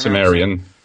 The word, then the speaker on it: Cimmerian: Cimmerian that you pronounce? – Cimmerian, yeah.